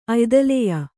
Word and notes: ಅಯ್ದಲೆಯ: kannada

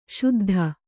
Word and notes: ಶುದ್ಧಿ: śuddhi

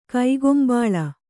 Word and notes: ಕಕ್ಕುಂಬಳ: Kannada

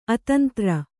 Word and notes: ಅತಂದ್ರ: atandra

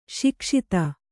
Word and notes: ಸೊಗಸಿತು: sogasitu

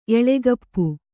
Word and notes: ಎಲುಕಪ್ಪಿ: kannada

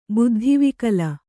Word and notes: ಬುದ್ಧಿವಿಕಲ: buddhi vikala

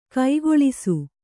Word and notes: ಕುಕ್ಕುಳಿಸು: kukkuḷisu

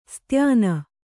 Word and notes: ಸ್ತ್ಯಾನ: styāna

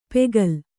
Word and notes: ಪೆಗಲ್: pegal

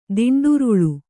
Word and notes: ದಿಂಡುರುಳು: diṇḍuruḷu

